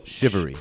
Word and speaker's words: Shivery: It's like the word chivalry minus the L. Shivery.